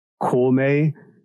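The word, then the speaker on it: Kōmei: That's the Japanese pronunciation of the kanji. So it's okay, the Japanese... Yeah, it's like how Zhuge Liang's... formal name, like the shortened name in Japanese is pronounced Koumei, but the title of the show, as you see in English, is Your Boy Kong Ming.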